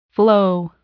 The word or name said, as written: flow